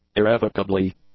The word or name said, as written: irrevocably